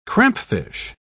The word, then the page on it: crampfish: British English